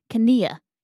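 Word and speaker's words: Knie: It looks like knee in English, but it's pronounced knee. You literally pronounce K, N, and both E's.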